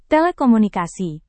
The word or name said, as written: telekomunikasi